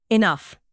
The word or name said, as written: enough